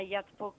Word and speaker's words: Yachtsport: Ya lo has dicho bien, se llama Yachtsport Cartagena o Puerto Yachts, Cartagena, como queráis.